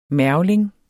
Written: mergling

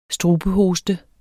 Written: strubehoste

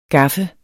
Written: gaffe

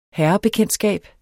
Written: herrebekendtskab

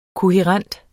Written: kohærent

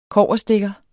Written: kobberstikker

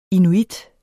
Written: inuit

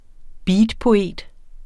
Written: beatpoet

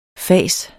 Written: fas

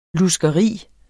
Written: luskeri

